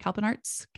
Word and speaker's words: Kalpana Arts: Kalpana Arts. Did I say that right? Kalpana Arts? – Yeah.